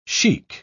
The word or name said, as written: shaikh